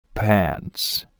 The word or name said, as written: pants